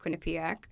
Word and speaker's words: Quinnipiac: So I was in Connecticut in college because I went to Quinnipiac.